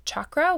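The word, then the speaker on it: chakra (चक्र): So I pronounce it chakra with the ch sound, the ch.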